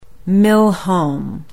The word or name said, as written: Millhome